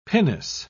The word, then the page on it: pinnace: British English